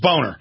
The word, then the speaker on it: Boehner: I mean, that's how it's pronounced. It's German. It's Boehner. I don't know why Boehner is getting away with what he does, but he's out there lying about the unemployment.